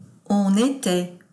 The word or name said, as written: on etait